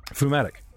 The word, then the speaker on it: pneumatic: Pneumatic. Spelt like phlegm. P-N-E-U, pneumatic.